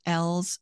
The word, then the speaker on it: Ells: I would recommend to your listeners to get Kimberly L's book, E-L-L-S is how you pronounce her last name.